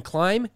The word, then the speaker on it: climb: No, is the B silent in climbing? – No, the B is silent in climb, climbs, climbed, and even climber.